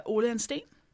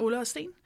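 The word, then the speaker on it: Ole & Steen: Ole and Steen. – Oh, so cool when you say it. – Ole and Steen.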